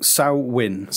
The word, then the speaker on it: Samhain: So, how do you pronounce it? Because I've seen... – Samhain. – Samhain. – But it looks like Samhain.